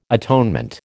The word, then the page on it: atonement: American English